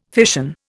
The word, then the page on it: fission: Indian